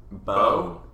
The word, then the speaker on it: Beau: Bo. – Ooh. – How do you spell that? – B-E-A-U.